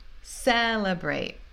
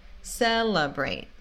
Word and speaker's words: celebrate: Mas a segunda e a última, porque não tem esse stress, a primeira sílaba é tônica, enemy, enemy, parece tipo como se tivesse um U, né? Enemy. E celebrate. Celebrate.